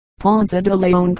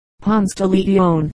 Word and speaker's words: Ponce de Leon: Footnote 1, Ponce de Leon, Ponte de Leon, or, in English, Ponce de Leon. Many persons now prefer the English pronunciation of all these Spanish names.